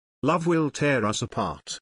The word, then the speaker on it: Love Will Tear Us Apart: Love Will Tears Apart. – Errou! – Sua pronúncia está nada a ver. Corrija isso, Debbie's. Love Will Tear Us Apart.